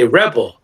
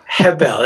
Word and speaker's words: Rebel: So as an American, we say Rebel. – Yes. – How do you say it with the Brazilian? – Hebel. – Hebel. It's almost like an H, huh? – Yes.